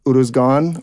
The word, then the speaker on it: Uruzgan: am I pronouncing this right? Uruzgan? – That's right.